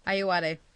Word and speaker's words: Ayoade: It's like how the IT crowd had the same actor, Richard Ayoade, is that how you pronounce his last name? – Ayoade.